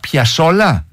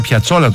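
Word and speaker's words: Piazzolla: Και Άστορ Πιασόλα. – Σωστότατα. – Μισό λεπτό. Το άκουσα να το λέει. Εγώ τον έλεγα Πιατσόλα τόσο καιρό.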